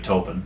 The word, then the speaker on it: Taupin: I didn't know anything about Bernie Taupin. – Mm-hmm. – Am I saying that right? – Yeah.